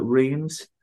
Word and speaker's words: Reims: You spell it R-E-I-M-S, so people call it Reims, but actually the way the French pronounce it is Reims.